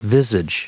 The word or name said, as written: visage